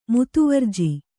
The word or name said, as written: ಮುತುವರ್ಜಿ